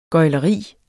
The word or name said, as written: gøgleri